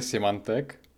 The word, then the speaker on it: Symantec: Myslím si, že správná výslovnost je Symantec, než zase se ozve nějaký čtenář, že mluvíme jak Budani.